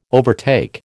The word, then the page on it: overtake: American English